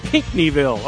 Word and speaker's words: Pinckneyville: Thank you, for your call from Pinckneyville, Illinois. I hope I said that right. – Pinckneyville.